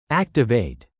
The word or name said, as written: activate